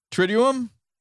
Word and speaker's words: Triduum: Into the Triduum. Is that how you say it? – The Triduum? Triduum. – Triduum? – There you go.